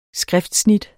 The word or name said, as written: skriftsnit